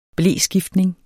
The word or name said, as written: bleskiftning